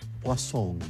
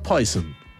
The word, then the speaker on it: Poison: y la ciútica de la vendedora lo pronunciaba Poisson. Poisson, no Poison.